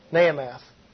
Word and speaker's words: Naamah: Of course, his father's name was Solomon, and his mother's name was, if I can pronounce it correctly, Naamath. N-A-A-M-A-H. Naamath.